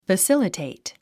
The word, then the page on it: facilitate: fəsílətèit; ファッシリテェイト